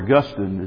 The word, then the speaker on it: Augustine: And then under the amillennial view, you have Augustine, or Augustine is probably the correct way to say it.